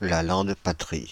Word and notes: La Lande-Patry: France (Île-de-France); /la lɑ̃d.pa.tʁi/

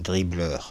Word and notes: dribbleur: /dʁi.blœʁ/

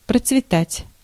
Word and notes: fleurir: /flœ.ʁiʁ/; France

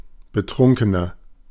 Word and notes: betrunkener: /bəˈtʀʊŋkənɐ/

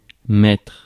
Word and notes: mettre: [mɛtʁ]; France

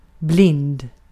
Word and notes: blind: American English; /blaɪnd/

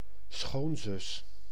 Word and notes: käly: /'kæ.ly/

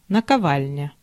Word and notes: наковальня: /nəkɐˈvalʲnʲə/; nakovalnja

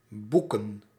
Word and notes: boeken: /ˈbu.kə(n)/

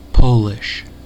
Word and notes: polish: American English; /ˈpɑl.ɪʃ/

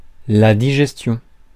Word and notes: digestion: France; /di.ʒɛs.tjɔ̃/

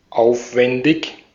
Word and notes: aufwendig: [ˈʔaʊ̯fvɛndɪç]

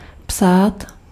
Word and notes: écrire: France; IPA: /e.kʁiʁ/

